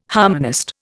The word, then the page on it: harmonist: Indian